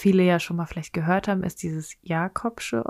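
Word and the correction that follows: Jacobsonsche: Organ. Spricht man das so aus? – Jakobsonsche Organ.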